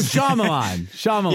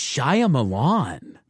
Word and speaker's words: Shyamalan: Oh, Shyamalan. – Yeah, yeah. Sorry, I thought you said, I was like, who's Shia Milan? – Oh, I said it wrong.